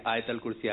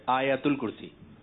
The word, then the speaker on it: آیت الکرسی: جی, جیسے یہی چل رہا ہوتا ہے کہ آیت الکرسی آیت الکرسی, اس کا درست تلفظ ہے آیت الکرسی.